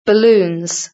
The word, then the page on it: balloons: British English